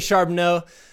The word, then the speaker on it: Charbonneau: I almost mispronounced that again, like an idiot. It's Brittany Charbonneau.